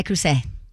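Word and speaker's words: Le Creuset: The way you're saying it, I call it Creuset. – You don't pronounce it with the T at the end, but...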